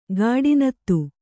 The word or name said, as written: ಗಾಡಿನತ್ತು